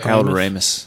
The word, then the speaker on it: Ramis: Harold Ramos or however you say his name. – Harold Ramis.